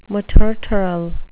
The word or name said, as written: materteral